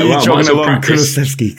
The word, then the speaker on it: Kulusevski: With Kulusevski, who it's weird to think about the actual football. – You said his name right.